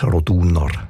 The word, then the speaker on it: Roduner: Also üblicherweise betonen wir ja Namen in der Deutschschweiz, wo drei Silben haben, immer auf der ersten Silbe. Also Andereck, Zollinger, Bertschinger, Ochsenbein. In dem Fall ist es aber eher die zweite Silbe, die betont Roduner.